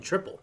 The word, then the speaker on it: Tripel: And I actually had to go to that Google site and said, how do you say T-R-I-P-E-L? – Triple. – But you say it just like you would the other version of triple.